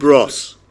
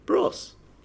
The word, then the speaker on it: Bros: Bros. – Wanted to be. – Toss. – What? – Bros. – It's rhymes with toss.